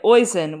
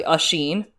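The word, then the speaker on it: Oisín: and oisin, which does not rhyme with hoisin sauce. It's actually usheen.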